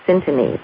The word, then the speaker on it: syntony: H-A-I-R-S-Y-N-T-O-N-Y. Syntony. And syntony sounds, it rhymes with symphony, but it's syntony, and it's from the Greek word syntonos, meaning balance, attunement, and harmony.